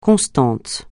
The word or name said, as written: constant